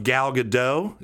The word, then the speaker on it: Gal Gadot: Similar to Henry Cavill's famed mustache in the Justice League reshoots, what aspect of Gal Gadot, is that right? – Yep.